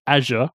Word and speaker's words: Azure: First of all, how did your Azure, as I'm told it's supposed to be pronounced, how did your Azure courses go? – All right.